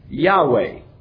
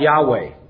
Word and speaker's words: Yahweh: The name by which God revealed himself was his personal name, Yahweh. That's how we pronounce it.